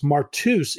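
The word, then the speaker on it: martus (μάρτυς): What's interesting is if we unpack that, Mark, then we see that the witness in the Greek is pronounced martus. It's literally where we get our modern word martyr.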